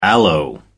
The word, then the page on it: aloe: American English